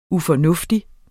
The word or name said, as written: ufornuftig